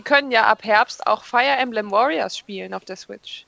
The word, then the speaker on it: Fire Emblem: Ja, Fire Emblem.